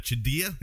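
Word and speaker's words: Chadiha: Jeffrey Chedia, is that what it is? – Correct. – Yes.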